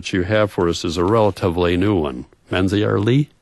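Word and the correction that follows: Manziarly: So how to pronounce it is Marcel de Manzierli.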